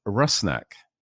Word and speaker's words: Rusnak: So that you can all be better prepared for my goal-scoring announcements this season, the pronunciation of our new designated player's name is Rusnak, Albert Rusnak.